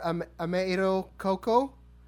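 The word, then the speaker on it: Ame-iro Cocoa: Ameiro Koko. Am I pronouncing that correctly? – That's pretty good.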